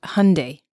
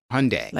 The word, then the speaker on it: Hyundai: Apparently it says it's pronounced Hyundai. – Hyundai. – Like Sunday.